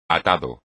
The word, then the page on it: atado: espagnol